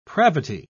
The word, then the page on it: pravity: British English